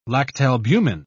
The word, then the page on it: lactalbumin: British English